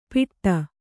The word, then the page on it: ಪಿಟ್ಟ: piṭṭa